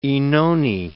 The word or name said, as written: Oenone